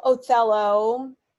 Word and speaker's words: Othello: So it's like, okay, I know Othello. I don't know. Do you pronounce the H or not? – Yes, I do. Othello.